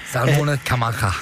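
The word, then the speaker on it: Salmones Camanchaca: Kan du udtale det? Salmones Camancha? – Salmone Camacha. – Ja, okay. Ja, fint.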